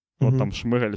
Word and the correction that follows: Шмигаль: Як правильно, блін, його е, прізвище вимовляється? Ден. Шмига. Називаю його Ден. Шмигаль. Ден, окей. Шмигаль Денис... чи Шмигаль? Шмигаль. Шмигаль.